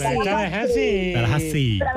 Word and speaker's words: Tallahassee: Tallahassee.